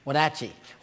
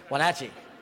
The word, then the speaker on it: Wenatchee: Is it Wachahatchee? How do you say that? Wenatchee. Wachahatchee-natchee. Wenatchee. Wenatchee.